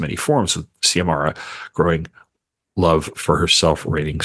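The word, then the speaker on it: Xiomara: Publishers Weekly said debut novelist Acevedo's free verse gives Ziamara, how do you pronounce the main character's name? I want to say Ziamara, but I'm not. – Ziamara? Okay. Yeah, because it's on, well, one of her poems goes through that pronunciation. – Okay, Ziamara. Yeah. Okay, all right. Yeah. So I was correct.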